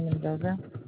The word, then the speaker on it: Mendoza: Angelina Mendoza.